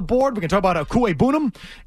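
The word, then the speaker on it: Okwuegbunam: Okuwebunam? Is that how you pronounce it? – Okuwebunam. – Okuwebunam. Great name.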